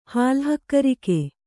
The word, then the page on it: ಹಾಲುಹಕ್ಕರಿಕೆ: kannada